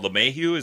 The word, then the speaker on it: LeMahieu: Assembly probably went first and went forty seconds, and then Vos called LeMahieu. Is that how you say his name? Yeah. Vos called.